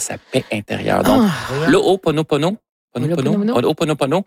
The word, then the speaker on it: Ho'oponopono: Non, c'est une philosophie hawaïenne de, je vais le prononcer comme il faut, de l'O-O. Ho'oponopono, le Ho'oponopono,